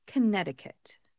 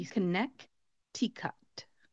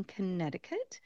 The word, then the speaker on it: Connecticut: When you look at the spelling, it looks like it should be Connecticut. Connecticut. However, we do not pronounce that middle C in front of the T in Connecticut.